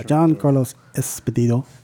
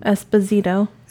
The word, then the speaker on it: Giancarlo Esposito: Yeah, Giancarlo Esposito. – There's no S on the end of his name. Giancarlo. – So, Giancarlo Esposito? – Esposito. – Esposito, okay.